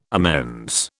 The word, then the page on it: amends: British English